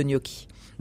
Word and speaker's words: gnocchi: Euh, le poisson blanc en bouillon de thé il est gnocchi. gnocchi, c'est ça, ça se prononce comme ça Gnocchi. gnocchi. Euh, les gnocchi étant, euh, enfin, fabriqués à base de pommes de terre.